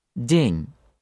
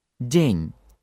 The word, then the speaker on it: день: And it will be день for day, день, день, день. The words mean exactly the same in both languages, but when you pronounce the Russian words, there simply is no such powerful flow of air at the beginning.